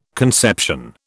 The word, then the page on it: conception: British English